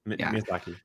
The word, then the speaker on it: Miyazaki: This movie, I don't want to butcher his name, but is it Miyazaki, or how do you pronounce his name? – Miyazaki.